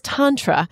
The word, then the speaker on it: Tantra: I tried to say it right. He says it right. It's Tantra,